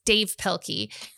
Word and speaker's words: Dav Pilkey: I will say Dav so I know how to spell it correctly. It's Dave Pilkey.